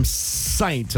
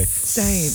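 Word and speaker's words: Ssaint: And Elle from Saint. And I say it like that because it's S-S-A-I-N-T. – Saint.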